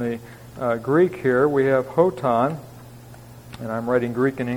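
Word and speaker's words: hoton (ὅταν): The word when is actually whenever, hoton in the Greek. And maybe just a... and you'll notice I'm pronouncing the rough breathing as more of an H sound, which may be a little bit different here.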